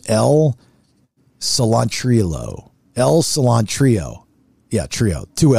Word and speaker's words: Cilantrillo: Well, I'll tell you. It's a C-I-L-A-N-T-R-I-L-L-O. Cilantrillo. Like cilantro, okay.